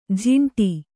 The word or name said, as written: ಝಿಂಟಿ